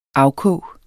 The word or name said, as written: afkog